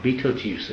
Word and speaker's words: Betelgeuse: And there's one big star there called Betelgeuse, or Betelgeuse as someone pronounced it.